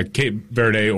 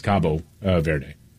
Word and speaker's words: Cape Verde: Yeah, Cape Verde, or in Portuguese, it's Cabo Verde.